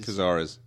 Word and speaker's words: Cazares: peter Causaries. Cazares. Kazar. How do you know that? Because he's written this before.